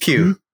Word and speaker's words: Pugh: Florence Pugh.